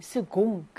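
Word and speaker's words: second: 4번 스공이라고 해서 두 번째의라는 뜻이죠. 이거는 여러분들이 영어 때문에 그 세컨드라고 읽으시는 분들이 많은데요. 이때는 스공, 그 발음이 나는 철자죠.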